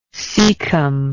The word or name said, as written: caecally